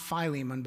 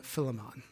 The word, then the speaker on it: Philemon: Apparently, according to the Greek experts, it should be, I don't know if I can do it, not Philemon, but Philemon. Philemon. Philemon. That's how you should say it. Because Li is the only strong vowel sound you're supposed to have.